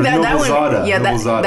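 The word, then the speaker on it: Noblezada: I think Noblezadeh. That one's pronounced exactly how it's spelled. Noblezadeh. – Noblezadeh, not Noblezadeh. I added the E.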